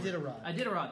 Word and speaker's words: Iditarod: Yeah, the Iridondack. I can't pronounce it. – Iditarod, thank you.